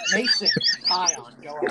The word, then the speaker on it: Taillon: How do you say his name? Taillon? – Wait, what? That's what I it's Toyan. It's Tanyan. Tanyan. – Tyon. – Tyon. – Yeah, that's how it's pronounced.